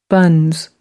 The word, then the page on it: buns: British English